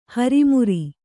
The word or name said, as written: ಹೊರಮಾರು